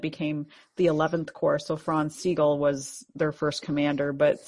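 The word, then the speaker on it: Franz Sigel: Let's say it right. I say Franz Siegel. – Franz Siegel was one of their, I should be able to say it right because I'm like half German.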